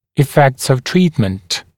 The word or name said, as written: effects of treatment